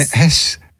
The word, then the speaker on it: Estes: I think he was E-S-T-E-S. I think he was in... – How do you pronounce that hurricane? – Estes. – Hurricane Estes? – Estes.